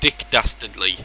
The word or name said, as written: dastardley